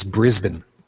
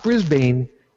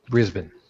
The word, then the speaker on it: Brisbane: It's Brisbane. Not Brisbane, Brisbane.